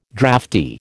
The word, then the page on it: drafty: American English